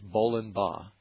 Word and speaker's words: Bollenbaugh: Bolenbaugh is spelled B-O-L-L-E-N-B-A-U-G-H. Sounds like bowling ball, but it's Bolenbaugh Hill Road in Monroe.